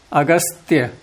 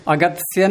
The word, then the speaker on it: अगस्त्य: हाँ, तो कभी कभी लोग जल्दी में गत्स्यानाम कर देते हैं, तकार पहले सकार आगे। अगस्त्य, अगत्स्य नहीं अगस्त्य, उच्चारण